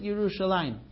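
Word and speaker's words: Yerushalayim (ירושלים): We pronounce it Yerushalayim, but the writing is like Yer'eh and Shalem, Yerushalem.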